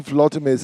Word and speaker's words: Volodymyr: What do Lech Kaczynski, Volodymyr Zelensky and Glenda Jackson have in common? – You pronounce that really well.